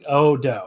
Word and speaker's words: Odo: Odo. Oh, it's just like a long O and another long O at the end. Uh, the Odo tournament in Shing.